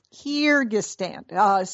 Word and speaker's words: Kyrgyzstan: She grew up in Bishkek, but that's how you pronounce it, Kyrgyzstan.